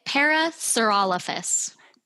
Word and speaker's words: Parasaurolophus: In the case of the Parasaurolophus, can you help me with the pronunciation? – So in Utah, we say it Parasaurolophus. – Parasaurolophus.